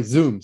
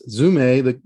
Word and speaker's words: Zume: It's, you know, yeah, that's, it's fun because some people pronounce Zume Zoom. So it sounds like Zoom via Zoom. You know, they pronounce both words the same, but it's Zume, the course, over Zoom, what we're using here.